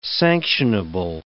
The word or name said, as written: sanctionable